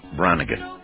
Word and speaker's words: Branigan: Anyway, that is called Self Control, and it's by Laura Branigan.